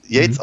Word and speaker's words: Yeats: Ähm, sagt, sagt nicht, äh, ja, okay, also, er, äh, er kann, er, er weiß nicht, dass, dass Yates, Yeats, äh, also Yeats, Yates mhm. ausgesprochen wird.